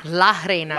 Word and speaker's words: La Reina: El Reina. Did I say that right? – Reina. – La Reina.